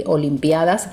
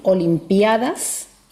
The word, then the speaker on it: olimpiadas: Pero sí, hay dos pronunciaciones distintas. Suele pasar con las palabras que proceden del griego, por ejemplo, ícono e icono, ¿verdad? Okay. Van con dos pronunciaciones distintas y las dos son válidas. Por lo tanto, podemos decir olimpiadas y olimpiadas, okay. con esa fuerza en la sílaba i, en la sílaba pi.